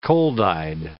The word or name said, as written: cold-eyed